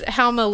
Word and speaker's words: Houma: Look at how Worcester is spelled and then say it. – Exactly. It's Houma, Louisiana.